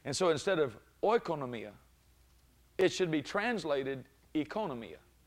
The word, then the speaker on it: oikonomia: Nomia, N-O-M-I-A, oikonomia. And even Strong's tells to pronounce it that way. But I found out that the O-I, like our word oil, is not pronounced that way. Actually, the Greeks don't pronounce it that way. Strong's does.